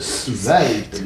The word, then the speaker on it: svelte: Bon, une prononciation correcte, svelte, n'est-ce pas? – Svelte. – Les gens instruits du midi, qui connaissent le mot et qui l'emploient, qu'est-ce qu'ils prononcent? Svelte. Bon, il est svelte.